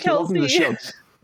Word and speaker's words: Kelce: Khalees or something like that? Khaleesi? I'm going to call her. I can't pronounce names. What it is, guys. Travis Kelce.